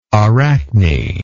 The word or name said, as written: arachne